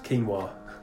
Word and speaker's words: quinoa: And for our listeners out there, quinoa is written quinoa, but pronounced quinoa.